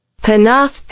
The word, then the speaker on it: Penarth: In January, Ncuti and Veradu are spotted filming on Penafre Pier. Have I said that right? I think I said that right. I probably haven't. Penarth Pier. Penarth Pier in the valley of Glamorg.